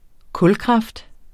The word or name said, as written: kulkraft